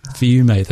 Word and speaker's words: Fiume: Fiume.